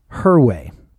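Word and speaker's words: Hirway: God, I got to scroll so far down to get this guy's name, but it's Rakesh Hurway.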